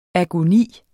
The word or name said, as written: agoni